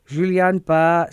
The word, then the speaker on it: Guillain-Barré: This syndrome called GBS or Guillain-Barré syndrome.